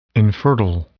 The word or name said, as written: infertile